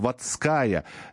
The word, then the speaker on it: Водская: А, ударение на последний слог. Водская Нина Петровна. Все, исправил.